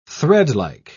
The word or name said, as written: threadlike